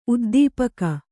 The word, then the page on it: ಉದ್ದೀಪಕ: uddīpaka